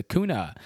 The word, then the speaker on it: kuna: So let's guess the kuna. That is what it is. Rhymes with tuna.